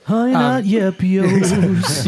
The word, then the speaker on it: Honey Nut Yepios: This Honey Nut Yepios, am I saying that right? – Yeah. – Is definitely, I've never had anything quite like this. – Honey Nut Yepios.